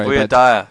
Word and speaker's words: Voy Dire: And then before that, we had a local band from Joburg called Voy Diary. – Dire. – I really don't know how to say it. I'm really sorry. – Voy Dire.